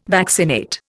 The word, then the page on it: vaccinate: Indian